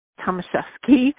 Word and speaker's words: Tomaszewski: My last name is spelled T as in Tom, Omaszewski. First name is Susan, and it's pronounced Tomaszewski.